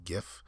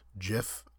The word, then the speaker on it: GIF: But, and I've understood that it's, you can pronounce it either way, GIF or JIF.